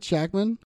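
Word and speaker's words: Shakman: Shackman? – Yep.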